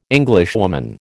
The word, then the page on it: Englishwoman: American English